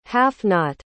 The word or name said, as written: half-knot